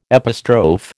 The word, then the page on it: epistrophe: American English